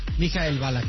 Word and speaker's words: Michael Ballack: ¿Michael Ballack? – Michael Ballack, sí.